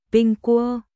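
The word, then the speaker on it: ping cua (苹果): Quả gáo được phát âm là ping cua, ping cua, đồng âm với chữ bình trong từ đêm bình an.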